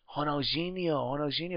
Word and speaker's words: Ronaldinho: I was like, and I said, how do you pronounce Ronald? Because if the D is a G, he was like, Ronaldinho.